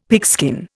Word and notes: pigskin: Indian

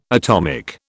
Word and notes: atomic: British English

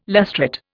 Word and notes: lustrate: Indian

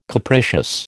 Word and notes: capricious: American English

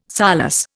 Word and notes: solus: Indian

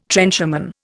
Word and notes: trencherman: Indian

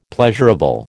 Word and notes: pleasurable: American English

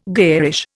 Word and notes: garish: Indian

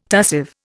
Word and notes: tussive: Indian

